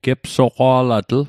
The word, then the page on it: gepsaqa'latl: Mi'gmaq